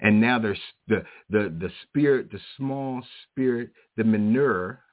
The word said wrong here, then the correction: menorah: My brother Shamar told me how to say this, but I tell you, I can't. Menorah. Menorah, that's it.